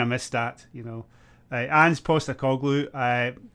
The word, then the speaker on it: Postecoglou: No, Ange Postecoglou.